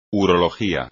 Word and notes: urología: espagnol

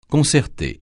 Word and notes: concerter: francais

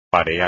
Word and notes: parear: espagnol

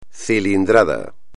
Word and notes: cilindrada: espagnol

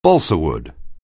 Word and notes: balsawood: anglais

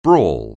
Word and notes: brawl: anglais

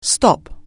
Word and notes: stop: anglais

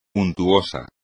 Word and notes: untuoso: espagnol

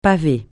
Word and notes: paver: francais

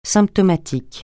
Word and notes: symptomatique: francais

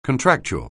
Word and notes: contractual: anglais

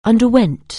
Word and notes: undergo: anglais

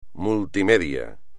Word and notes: multimedia: espagnol

